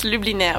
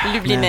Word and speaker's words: Lubliner: It's Lubliner, but it's really hard to say. – Okay. – Lubliner.